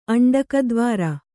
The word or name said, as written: ಅಂಡಕದ್ವಾರ